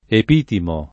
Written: Epitimo

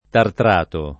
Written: tartrato

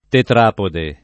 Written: tetrapode

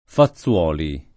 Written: Fazzuoli

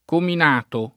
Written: Cominato

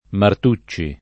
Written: Martucci

